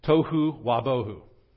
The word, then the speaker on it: Tohu wabohu: And it's pronounced in the Hebrew without form and void. Tohu wabohu. Tohu wabohu.